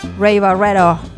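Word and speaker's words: Ray Barretto: Ray Barreto, así se pronuncia, es Ray Barre, el rey.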